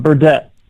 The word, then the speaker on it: Burdette: How do you say your last name? I forgot to ask you about that before we went live. I suck at math. – It's all good. Burdett.